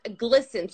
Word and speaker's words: GLSEN: and it's pronounced GLSEN,